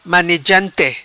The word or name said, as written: Manegante